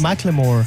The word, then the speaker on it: Macklemore: Macklemore.